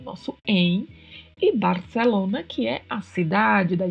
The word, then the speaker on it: Barcelona: Mas na Espanha, na maioria das regiões, o C tem uma sonoridade produzida com a língua entre os dentes. Barcelona, Barcelona.